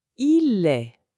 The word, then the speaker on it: est: Avec il, on dit il est. Il est. Le son est, comme dans mère ou frère, il est. Ici aussi, le T à la fin est muet, on ne le prononce pas.